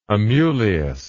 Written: Amulius

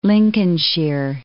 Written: Lincolnshire

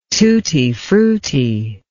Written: tutti frutti

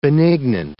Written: benignant

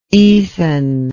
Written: ethan

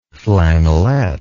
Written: flannelet

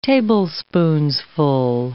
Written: tablespoonsful

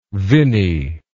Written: vinny

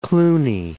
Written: Cluny

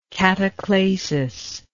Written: cataclasis

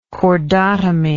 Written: chordotomy

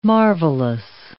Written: marvellous

